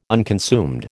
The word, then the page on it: unconsumed: American English